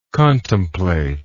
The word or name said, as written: contemplator